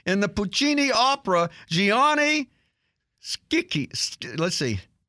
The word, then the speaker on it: Schicchi: No, it's a hard C. Schicchi. It's Gianni Schicchi. We're going to do that. I looked that up. That CH is a hard K.